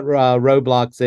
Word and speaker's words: Roblox: So tell us first of all, for those who are not gamers, tell us what Roblox is.